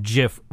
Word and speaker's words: GIF: You know what, that reminds me, before we started talking about wrestling, we were once again debating the pronunciation of GIF versus GIF. – it's a soft G.